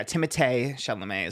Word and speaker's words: Timothée Chalamet: Timothée Chalamet is how you actually pronounce his name.